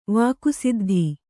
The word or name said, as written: ವಾಕುಸಿದ್ಧಿ